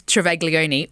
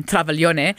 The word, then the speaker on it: Travaglione: So my name's Travaglione, or in Italian, Travaglione.